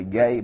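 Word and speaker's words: gay (γῆ): The word, it's pronounced actually gay, but it's spelled G-E.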